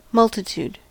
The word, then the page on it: multitude: American English